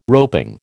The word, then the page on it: roping: American English